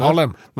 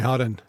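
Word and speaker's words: Haren: Harlem? – Nei, Harem. – Åja. – Uttales herr.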